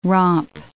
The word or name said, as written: romp